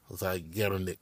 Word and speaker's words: Zeigarnik: Now, there is a psychological phenomenon called the, and I hope I'm pronouncing this right, Zeigarnik, Zeigarnik effect, is how it's pronounced.